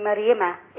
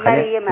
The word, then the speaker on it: مريم: الراء ديت مفخمة ولا مرققة؟ – الراء كده دي مفخمة. – تبقى الراء مفخمة تتنطق ايه؟ – رسول. – لا احنا مش في الرسول. احنا في كلمة مريم. – لا ده دي من التسكين. مريم. – مر ولا مر؟ – مريم.